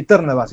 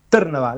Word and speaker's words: Trnava: ¿El último equipo fue el Spartak Trnava? ¿Puede decirse bien o no? – Sí, Trnava sería. – ¿Cómo? – Trnava.